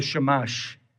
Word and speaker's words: Chumash: And this is the Jewish translation from a document I don't know much about, but called the Shamash. I think it's how you pronounce it, C-H-U-M-A-S-H. It says this.